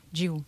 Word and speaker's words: Jiwu: That is one of the tracks on your new album called Jiwu. – Yes. – Am I pronouncing that right? – Yes. – Jiwu.